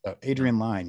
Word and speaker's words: Adrian Lyne: I said it wrong when we were talking about this movie earlier this year, but Adrian Lyne. Adrian Lyne, yeah.